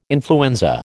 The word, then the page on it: influenza: American English